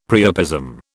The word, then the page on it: priapism: British English